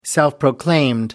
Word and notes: self proclaimed: American English

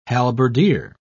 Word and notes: halberdier: British English

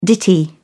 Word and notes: ditty: British English

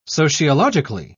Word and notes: sociologically: British English

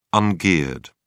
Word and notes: ungeared: British English

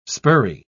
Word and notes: spurry: British English

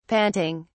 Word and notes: panting: British English

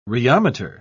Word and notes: rheometer: British English

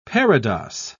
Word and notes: parados: British English

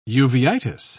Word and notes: uveitis: British English

